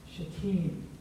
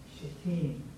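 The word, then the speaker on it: Shittim: And so you might be tempted to want to emphasize the first syllable, but the proper pronunciation is emphasizing that second syllable. Shatim. Shatim.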